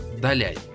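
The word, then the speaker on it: Далянь: В 2009 году переехали на север Китая, в город Даляй. Я надеюсь, правильное ударение. – Все правильно, да.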